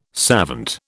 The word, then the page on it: savant: British English